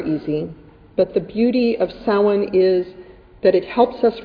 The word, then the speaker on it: Samhain: Samhain. There were a few giggles, and one very kindly adult gently and quietly told me that it was actually pronounced Samhain.